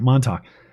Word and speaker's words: Montauk: I got to take the train to Montauk, however you pronounce that place. Do you know East Coaster? Montauk? Montauk, is that it? All right, Montauk.